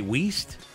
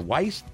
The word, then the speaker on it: Weist: Assistant special teams T.J. Weist. Or Weist. – W-E-I-S-T. I apologize if I'm mispronouncing his name.